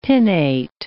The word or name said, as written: pinnate